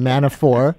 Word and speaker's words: Manafort: Alan Dershowitz on Meet the Press yesterday, frankifying the name Manafort, calling him Manafort, as for some reason he always does, with a silent T.